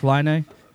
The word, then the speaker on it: Laine: Patrick Laine. You got to say it like Patrick.